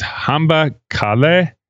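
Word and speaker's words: Hamba kahle: So go well is, it's Hamba Kale, I believe. And I know I pronounced it wrong, but it's Hamba, H-A-M-B-A, and then K-A-H-L-E. But the H-L-E almost sounds like a T-H.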